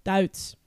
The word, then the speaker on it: Duits: Duits.